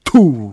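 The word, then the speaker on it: Tool: 툴은 발음을 이렇게 해줘야 돼요. 그래야 맛이 살아. 툴.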